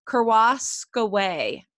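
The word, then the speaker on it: Kerascoët: but I think it's pronounced Kouroskoway. I think it's French,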